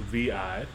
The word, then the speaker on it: Veeiye: So, apparently her name is pronounced V-I.